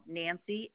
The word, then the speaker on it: Nancy: And Nancy, N-A-N-C-Y.